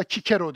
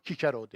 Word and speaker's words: Cicero: Cicero, aslında Cicero diye okunur, doğrusu bu. Çünkü Romalı onu Cicero derdi.